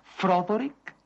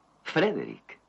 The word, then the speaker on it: Frederick: ¿Dice usted también Froderick? No, Frederick. ¿Y por qué no es Froderick Frankenstein? Porque no? Es Frederick Frankenstein.